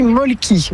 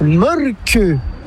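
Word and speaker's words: Mölkky: Inventé en Finlande en 1996, ce jeu de quilles s'écrit M-O-T-R-E-M-A-L-K-K-Y. – Ça s'écrit Molki, mais ça se prononce Molke. – Le Molke. – Plutôt un E qu'un E, mais pas un U. Comme ça fait Molke.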